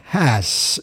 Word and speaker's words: Haas: Le nom d'un houblon créé par John Haas. je fais bien effort de prononcer le H, mhm.